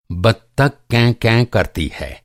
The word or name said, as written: बत्तक